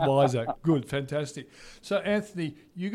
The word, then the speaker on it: WISR: Now, but also, even the business you work for, is it Wizzer? Is that the right pronunciation? W-I-S-R? – It's Wiser. – There you go. – Actually. – See, Wiser. Like, smarter, cleverer. It's Wiser.